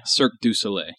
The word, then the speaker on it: Cirque du Soleil: It was like a, how do you pronounce that? The Cirque Soleil? – Cirque du Soleil.